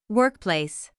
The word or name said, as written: workplace